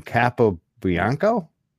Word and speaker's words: Capobianco: Valentino, how do we say his last name? Capobianco? – Yep, you got it right.